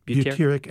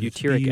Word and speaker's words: butyric: Butyric acid. – Butyric acid. – B-U-T-Y-R-I-C.